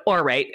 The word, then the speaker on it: Aurate: So for 15% off your first Orate purchase, you go to Orate. And by the way, this is how you spell that. A-U-R-A-T-E.